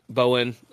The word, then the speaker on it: Bowen: So on like, yeah, pretty much every social media, it's just Philip Bowen Music. So it's Philip with one L, Bowen.